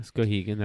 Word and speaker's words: Skowhegan: How did you pronounce it? – Skowhegan. – Skowhegan. – Skowhegan. – It looks like Skowhegan because it's got the W. – Yeah, Skowhegan. That's how Mainers would say it.